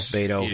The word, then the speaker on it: Beto: So, but going back to Beto, I hope I'm saying his name right. Not that is that right, Beto? – Yeah, Beto. – I think so. – Yeah, it's supposed to be the Spanish nickname for Robert.